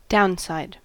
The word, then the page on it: downside: American English